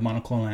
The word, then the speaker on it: monoclonal: So the monoclonal antibodies, am I saying that right? – Monoclonal antibodies, yes.